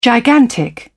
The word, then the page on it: gigantic: British English